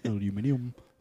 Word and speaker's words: aluminium: Would you like to know how we say aluminum in Britain? Aluminium.